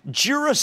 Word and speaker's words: Jira: Now, there's a Jira service desk. Am I pronouncing it? Jira? – Yep.